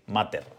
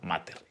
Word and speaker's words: Matter: A partir de ahora va a ser Mater, como se, como se escribe en castellano, pues lo voy a pronunciar así, Mater.